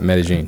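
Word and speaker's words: Medellín: That's how you say it, Medellin. – Medellin. Medellin, Like yeah. you know, people say Medellin.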